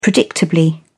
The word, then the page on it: predictably: British English